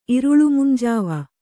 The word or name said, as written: ಇರುಳುಮುಂಜಾವ